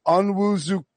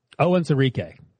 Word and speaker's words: Onwuzurike: Amari Rodgers and Levi Onwuzurike. I didn't get that right. How do I pronounce it? – Onwuzurike.